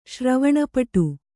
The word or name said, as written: ಶ್ರವಣಪಟು